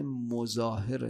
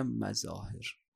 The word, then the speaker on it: مظاهر: خب تو بازار حبیب ابن مظاهر بهش رسید. ظاهرا تلفظ صحیح حبیب ابن مظاهر نه مظاهر.